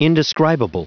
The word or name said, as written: indescribable